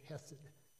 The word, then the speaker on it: chesed: It's chesed, or some pronounce it hesed.